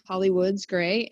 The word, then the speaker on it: Hawley Woods-Gray: Well, I'm on LinkedIn, Facebook, Instagram, Hawley Woods-Gray.